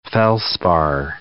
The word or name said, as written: felspar